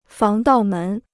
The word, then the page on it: 防盗门: fáng dào mén